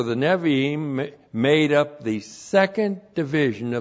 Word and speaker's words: Nevi'im: So point one, Zechariah is a part of the Hebrew canon known as the Nevi'im. That's N, Shiva, B, long I, Aleph, long I, M. Nevi'im.